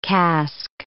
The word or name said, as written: casque